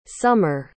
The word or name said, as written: sommer